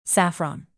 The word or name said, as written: saffron